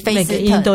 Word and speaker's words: First: 然后我这一次走了几条，大概那个少女峰那一边，嗯、就是像呃有一个地方叫做 First，写起来就是我们的 First。 – Okay. – 对，可是那个他他们的发音的话，就是菲斯特这样子，对对对。